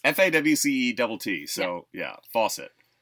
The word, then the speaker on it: Fawcett: Yeah, so we just got in a whole bunch of Captain Marvel, like, Fawcett, like Shazam comics from, like, the 40s.